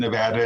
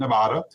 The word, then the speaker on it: Nevada: You said Nevada and not Nevada.